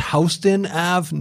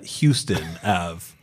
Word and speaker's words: Houston: It's pronounced Houston Ave, not Houston Ave.